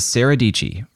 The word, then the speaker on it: Dicci: Her name is Sarah Dicci, rhymes with peachy, and she has more than 800,000 subscribers here on YouTube.